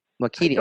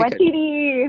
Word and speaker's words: Waititi: Waititi? – Taika Waititi. – Oh, I got it right. Okay. – Waititi.